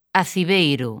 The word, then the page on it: Aciveiro: lingua galega